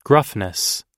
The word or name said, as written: gruffness